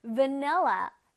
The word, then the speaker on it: vanilla: 바닐라가 아니라 뭐라고요? Vanilla, vanilla. 자, 바닐라의 맛처럼 이렇게 풍부하고 달달하게 한번 발음해보세요. Vanilla, vanilla. V니까 V, V, V. 기억할 수 있겠죠? V, V, V. 아랫입술로 이렇게 통통통 튀어주는 거야. V, V, V.